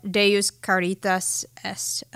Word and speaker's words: Deus Caritas Est: So Pope Benedict, he has this encyclical, Deus Caritas Est, and I don't speak Latin.